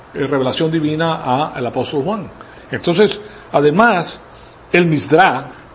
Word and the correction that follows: Midrash: o Midrash, se pronuncia Midrash,